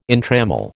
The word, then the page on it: entrammel: American English